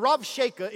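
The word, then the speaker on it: Rabshakeh: The B is pronounced as a V. The Rav Sheka is found in 2 Kings 18 and Isaiah 36.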